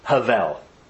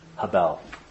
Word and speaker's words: hebel: And you pronounce it actually Havel. It's spelled H-E-B-E-L. In the original Hebrew, there's no vowels, but Havel is how you say it. I've also heard it said Havel.